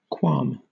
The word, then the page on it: qualm: British English